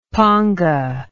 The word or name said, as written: paanga